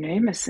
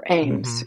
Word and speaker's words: Amis: or Ames, mm-hmm. if I'm mispronouncing it American-wise, right? Yeah. How do you pronounce it? Ames? – I say it Amis. I think it's Amis. – Okay.